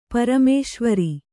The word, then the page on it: ಪರಮೇಶ್ವರ: paramēśvara